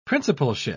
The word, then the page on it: principalship: British English